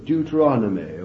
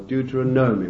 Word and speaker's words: Deuteronomy: Over and over and over and over again, I believe I counted in the book of Deuteronomy, or Deuteronomy, whichever pronunciation you prefer, I counted, I think there were 30 or 40 references to this great truth of possessing, way back into Numbers.